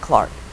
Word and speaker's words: Clerk: So the British usually, it's spelt clerk, the British usually pronounce it Clark.